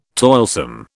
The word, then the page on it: toilsome: British English